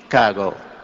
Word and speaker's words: Chicago: Once I was listening to him on television talking about the great city, our great city of Chicago, and he said, you don't pronounce it she. Chicago. It's chi, like chick, you know, or like chicken. Chicago.